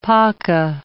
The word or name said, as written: paca